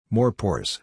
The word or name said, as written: more pores